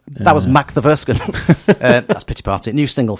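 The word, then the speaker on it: Makthaverskan: mock. It's Mock the Furskin. – Emphasis on. – Yes, emphasis on the first and third syllables. Yes. And the M-A-K is an M-O-C-K sound to us.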